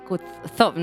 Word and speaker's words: Kutuzov: El 7 de septiembre de 1812, las tropas de Napoleón se enfrentaron a las fuerzas del general Michajil Kutuzov.